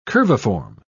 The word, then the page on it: curviform: British English